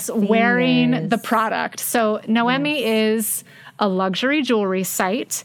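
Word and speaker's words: Noémie: We are pronouncing that right. We're reading it phonetically. Noemi. They wanted to make sure. It's N-O-E-M-I-E.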